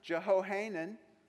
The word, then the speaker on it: Jehohanan: He was the son-in-law of Shechaniah, the son of Ara, and his son, Jehohanan. That's how you say it.